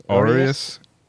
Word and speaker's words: Aureus: Aureus. – It's A-U-R-E-U-S.